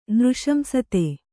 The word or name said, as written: ನೃಶಂಸತೆ